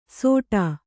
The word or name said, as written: ಸೋಟ